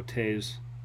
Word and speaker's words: Toews: I don't know how you pronounce that. – Oh, Toews.